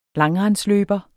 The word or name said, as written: langrendsløber